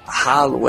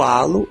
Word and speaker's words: Halo: que não é Halo, é ou Halo ou Halo, que é a pronúncia em português do Brasil ou em inglês.